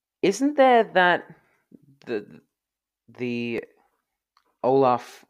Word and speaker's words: Olaf: I'm guessing that it's Olaf. – Olaf.